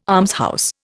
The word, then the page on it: almshouse: Indian